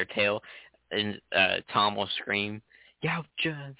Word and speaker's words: Yautja: The Yautja. – That's it. – Yeah. It looks super complicated, and then I heard someone say it, and I was like, it's two syllables?